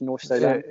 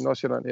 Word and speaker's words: Nordsjælland: So one club like Farum became how do you say that in Danish? Nordsjælland. – Yeah, exactly. Nordsjælland.